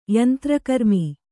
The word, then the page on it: ಯಂತ್ರಕರ್ಮಿ: kannada